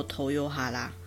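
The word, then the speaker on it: 豊原: 日本的东日本的东北本线那边也有一个站叫做丰原，丰原就念作头优哈拉，头优哈拉，这这都是很有名的站。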